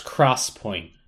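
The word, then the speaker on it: XPoint: They should be refocusing on their solid state technology, their 3D X point, pronounced cross point.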